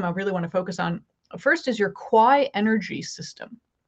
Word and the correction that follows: Quai: Key. Like the key energies. Yeah. But it's spelled QUAI, so I screwed that up. – Oh no, listen, it's French, so it's like keys are like things that keep a shore from washing away, like on a riverfront or like beach.